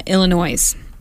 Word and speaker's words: Illinois: This group just in, actually, Illinois. This group is paying $2 million. – You don't pronounce the S in Illinois, by the way.